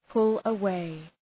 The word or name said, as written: pull away